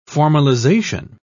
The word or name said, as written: formalization